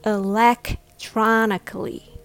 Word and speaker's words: electronically: Remember to practice electronically, with the stress on the third syllable, tron.